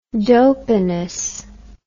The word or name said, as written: dopiness